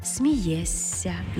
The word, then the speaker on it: смієшся: Не «смієшся», а «смієсся». Не «спілкуєшся», а «спілкуєся».